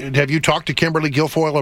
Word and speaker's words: Guilfoyle: Guilfoyle.